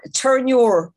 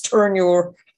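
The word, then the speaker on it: Terenure: It's not Turnure. That's how you say it on the south side. It's Turnure.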